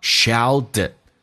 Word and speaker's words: shouted: His mother shouted, 母亲大声地喊道 shouted 这个单词 T 浊化成 D 在美语当中两个元音中间所加的清辅音要被浊化叫 shouted, 最后尾音的 D 也可以不用发音，气流阻塞。